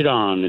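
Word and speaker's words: Çırağan: Chiragan. – Okay. – Chiron, it's pronounced.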